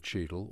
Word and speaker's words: Cheadle: Now we've set out the geography of Cheadle, or King's Abbot for you.